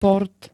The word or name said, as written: port